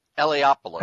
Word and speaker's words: Eliopoulos: Eliopoulos.